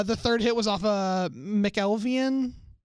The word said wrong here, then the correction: McElvain: McElvain. – McElvain, that's how you pronounce names.